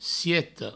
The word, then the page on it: sieta: sieto